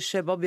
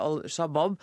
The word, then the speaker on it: Al-Shabaab: Nå registrerer jeg at du sier Shebab, jeg sier Al-Shabaab. Hva er grunnen til at vi uttaler dette forskjellig? – Det får jeg mange spørsmål om. – Ja, uttalen, og du må bare oppklare det. – Ikke sant? Den uttalen varierer litt hvor du er okay.